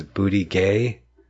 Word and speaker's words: Buttigieg: Pete Buttigieg. I think the G is silent on the end, so it's Buttigieg.